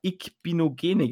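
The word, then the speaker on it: Igbinoghene: Ähm, sie haben sich den Cornerback Noah Ikbinogene geholt. Ich hoffe, ich habe es richtig ausgesprochen. – Ähm, du hast es anscheinend sogar richtig.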